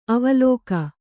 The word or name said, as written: ಅವಲಕ್ಕಿ